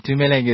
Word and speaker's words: Tumuleng: I know Tumi, but Tumuleng, is that correct? Am I saying it right? – Yeah, Tumuleng is the obviously the long version, but you know the short version. I'm known as Tumi.